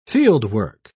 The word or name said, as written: fieldwork